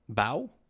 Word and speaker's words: Bow: Am I saying that right? – Bow? Yep.